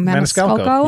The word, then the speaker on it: Maniscalco: I always say his last name wrong. – Maniscalco. You had it.